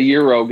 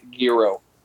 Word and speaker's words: gyro: It's going to be one of the 5 different pronunciations, but you're always going to hear me say gyro. – Gyro. What did the guy from New York say, though? – Euro. Euro. No G.